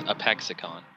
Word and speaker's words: Apexicon: Well, it rhymes with lexicon, so it's Apexicon.